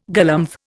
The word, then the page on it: galumph: Indian